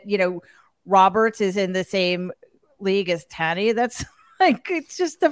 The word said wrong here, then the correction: Tawny: Correction. I think it's pronounced Tawny.